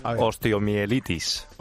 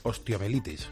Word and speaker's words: Osteomielitis: Osteomielitis. – Osteomielitis.